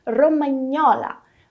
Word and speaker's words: romagnola: Mas voltando ao som dessa palavra, olha ali de novo o GN sendo pronunciado como NH. Romagnola, romagnola, beleza?